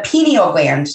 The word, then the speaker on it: pineal gland: So I have my first topic here is the pineal gland. Did I say that right? – Yes, you did.